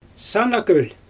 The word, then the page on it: sana gölJ: Xyzyl